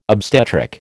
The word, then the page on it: obstetric: American English